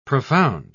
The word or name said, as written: profound